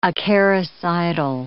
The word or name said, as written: acaricidal